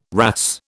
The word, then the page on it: wrasse: British English